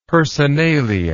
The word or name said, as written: personalia